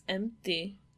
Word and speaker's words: empty: There's no pee there. – No, because it's empty. – Empty. – And you don't actually pronounce the pee in empty.